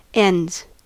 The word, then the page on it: ends: American English